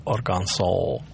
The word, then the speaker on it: Арканзас: Вот Кот утверждает, что слово «Арканзас» должно произноситься как «Арканзол». – Да. – Но местные так говорят.